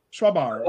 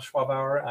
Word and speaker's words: Schwabauer: Schwabauer.